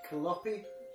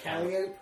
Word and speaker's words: calliope: I have an issue because it's like, how do you pronounce that word? Is it Kaloppy? You know, where you crank the handle and like it's clang music. You know what I mean? – Yeah. Oh, yeah. – Calliope.